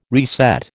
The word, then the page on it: reset: American English